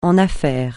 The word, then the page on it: en affaires: francais